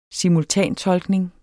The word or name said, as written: simultantolkning